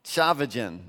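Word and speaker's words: Tchividjian: You got a quote on your note sheet from a pastor named Tullian Chavajan. You look at that last name, you're like, I don't know how you got Chavajan out of that, but that's how you say his name, right?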